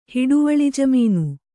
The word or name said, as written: ಹಿಡುವಳಿಜಮೀನು